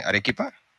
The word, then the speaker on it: Arequipa: es en Arequipa, ¿cómo se dice? Arequipa, ¿sí, no? – Sí.